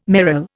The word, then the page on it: merle: Indian